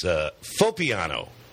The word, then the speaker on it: Foppiano: Joining us today on California Wine Country, brought to you by Bottle Barn, with Dan Berger and Barry Herbst, it's Foppiano, right? – Correct.